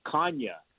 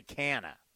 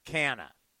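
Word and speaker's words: Canha: You know, he he just yeah, but his family they go by Canna. – Yeah, the, per, the, the, the, the uh, Portuguese pronunciation is that, but his family, as he said, he says, yeah, but we go by uh, Canna.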